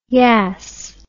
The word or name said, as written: yass